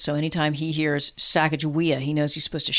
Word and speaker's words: Sacagawea: It's Sacagawea.